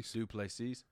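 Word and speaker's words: Du Plessis: Last fight, losing, getting knocked out by, say his name because we all fuck it up. – Duplessis. – Yeah, it's not that hard to say.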